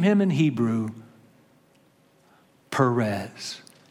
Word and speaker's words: Perez: Sometimes it's spelled P-H in your translations, but it is P. It's pronounced Perez.